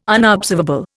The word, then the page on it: unobservable: Indian